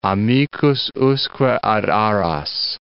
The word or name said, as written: amicus usque ad aras